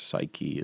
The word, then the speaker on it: Psyche: So that was I Am The Morning with Song of Psyche or Psyche, but I think if it's English, Psyche is the way you pronounce that with the E at the end.